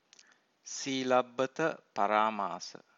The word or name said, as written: silabbata-paramasa